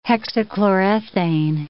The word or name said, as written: hexachlorethane